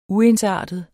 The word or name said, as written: uensartet